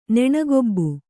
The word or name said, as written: ನೆಣಗೊಬ್ಬು